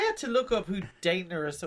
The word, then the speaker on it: Daenerys: as Daenerys. Is that how you say her name? Such a long time. – Yeah, Daenerys. – Daenerys.